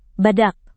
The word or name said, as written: badak